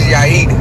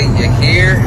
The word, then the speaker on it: Yair: My name is Yair, but white people always say Yair. They always say something like that.